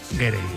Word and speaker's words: Gere: estaba pensando yo que si te apellidas Ger, bueno, ¿cómo se dice Ger o Gerde? Gerde. – Me ha hecho gracia porque has dicho Richard Gere y luego el hijo era Gere.